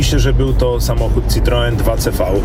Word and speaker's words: Citroen: Z tego, co pamiętam, to był Citroen 2CV.